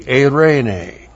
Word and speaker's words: eirene: Looks like that in the Greek, E-I-R, long E-N, long E, eirene.